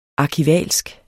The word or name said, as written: arkivalsk